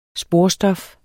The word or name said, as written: sporstof